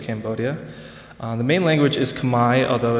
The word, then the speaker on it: Khmer: Yeah, so it's spelled Khmer in English, but it's pronounced Khmer, and it draws a lot of influences from the surrounding countries.